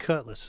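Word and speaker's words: Kutless: And it's Cutlass. It's spelled with a K. – Yeah, K-U-T-L-E-S. – Yeah. – E-L-E-S-S.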